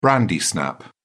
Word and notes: brandy snap: British English

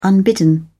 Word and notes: unbidden: British English

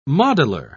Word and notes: modeller: British English

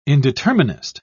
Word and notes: indeterminist: British English

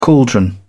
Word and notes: caldron: British English

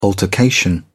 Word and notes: altercation: British English

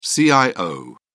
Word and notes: cio: British English

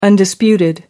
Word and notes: undisputed: American English

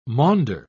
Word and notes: maunder: British English

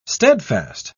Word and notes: stedfast: British English

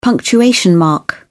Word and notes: punctuation mark: British English

